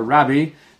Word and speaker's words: Rabbi: And he said, Rabbi, or Rabbi, I think is how that's more often pronounced in Hebrew, but it means teacher.